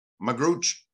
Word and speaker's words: Magrutsche: Magrutsche.